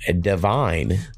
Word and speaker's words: D'VYNE: The next song we're going to go with is from an artist that goes by the name of Divine. That's how I'm pronouncing it. It's D'VYNE. I pronounce it Divine.